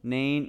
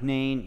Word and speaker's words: Nain: Started with an N, rhymes with Ain. What do you think it was? Nain, yeah, like Nain. It's kind of like Nain, yeah.